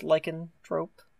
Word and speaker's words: lycanthrope: the how do you pronounce lycanthrope? – Lycanthrope, thank you.